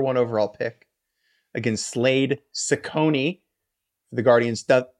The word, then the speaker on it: Cecconi: Just so everyone knows, C-E-C-C-O-N-I. Okay, that first C is going to be an S. That second C is going to be a K, and that's going to help you pronounce Ciccone correctly as we move forward, because he is a relevant character.